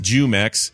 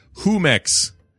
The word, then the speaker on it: Jumex: The Jew part of the word is not pronounced Jumex. It's pronounced more like Humex.